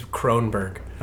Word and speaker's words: Kronberg: And before you say it wrong one more time, my name is Kronberg.